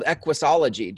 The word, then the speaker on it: Equusology: What I want to know, Carolyn, from you is you actually wrote a book called Equusology. Did I say it right? – You did say it right.